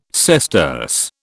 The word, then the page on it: sesterce: British English